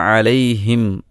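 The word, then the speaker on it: അലൈഹിം (عليهم): അൻഅംത അലൈഹിം ഗൈറിൽ മഗ്ദൂബി അലൈഹിം വലദ്ദാലീൻ. അലൈഹിം എന്ന് പറയുന്നിടത്ത് സുക്കൂനുള്ള മീമാണ്. അലൈഹിം എന്നതിലെ അവസാന അക്ഷരം ഏതാണ്? സുക്കൂനുള്ള മീമാണ്. സുക്കൂനുള്ള മീമിൻ്റെ ഉച്ചാരണം രണ്ട് ചുണ്ടും പൂട്ടുക എന്നതാണ്.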